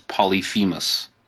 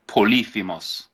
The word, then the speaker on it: Polyphemus: The common pronunciation is Polyphemus. If you wanted to say it in Greek with the accent, it's Polyphemus. Polyphemus, but most people say Polyphemus.